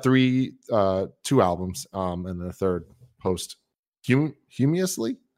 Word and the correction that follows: posthumously: How do you say that? – Posthumously. Posthumously posthumous yeah posthumously